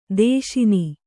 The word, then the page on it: ದೇಶನ: dēśana